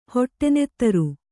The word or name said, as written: ಹೊಟ್ಟೆನೆತ್ತರು